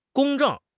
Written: 公正

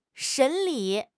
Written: 审理

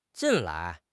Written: 近来

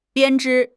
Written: 编织

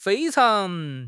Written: fei1 chang2

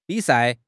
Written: bi3 sai4

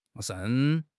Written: 盛

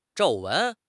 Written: 皱纹

